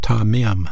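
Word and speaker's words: tamim (תמים): That word sincerity is a Hebrew word pronounced tamim, tamim, and it means wholeness or complete, undivided attention, integrity, making His will priority number one in your life.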